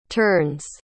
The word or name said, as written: turns